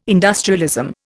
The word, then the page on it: industrialism: Indian